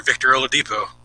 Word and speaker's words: Victor Oladipo: Mr. Pacer, Victor Oladipo. – Ah, that's how you pronounce those names.